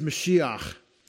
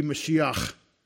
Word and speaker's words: Mashiach: In Hebrew, it's Mashiach, because the H at the end is a chet. This letter here, let me show you. This letter here in Hebrew is a chet, and it would be pronounced ch instead of ha, because there's two H's in Hebrew. There's a ha and a ha. So it would be Mashiach.